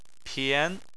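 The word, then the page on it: pian2: tone 2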